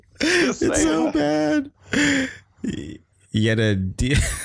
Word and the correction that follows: Yedidia: Am I pronouncing that right? – Okay. I don't know. – Okay, it's Yedidia.